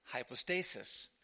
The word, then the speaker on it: hypostasis: Hypostasis is pronounced hypostasis. And the plural is hypostases.